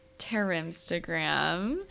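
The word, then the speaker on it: Tehrimstagram: So our listener of the week is Terimstagram. So that's spelled T-E-H-R-I-M Stagram.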